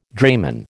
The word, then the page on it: drayman: American English